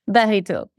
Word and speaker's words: Barito: My name is Chloe Barito. Which I think could be indeed has been pronounced so many different ways by American people, but Barito, yes.